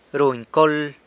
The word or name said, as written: runy col